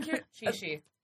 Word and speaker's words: Qixi: How do I say it again? – Chi-shi. – Chi-shi? Okay. – And on that note, I have to leave. – Oh. Yes. Aww. Thank you. We love you. – You're amazing. Bye. – The Chi-shi festival. – Chi-shi. Chi-shi. Chi-shi. – No, it's Chi-shi. – The Q-I-X-I.